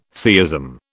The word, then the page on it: theism: British English